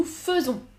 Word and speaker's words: faisons: F-A-I-T. Attention. F-A-I-S. F-A-I-S. F-A-I-T. Nous, nous faisons. Nous faisons. Écoutez la prononciation. Faisons. Pas faisons. Je fais, nous faisons. Différent. Nous faisons, vous faites. Attention, irrégulier. Vous faites F-A-I-T-E-S.